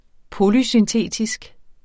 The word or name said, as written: polysyntetisk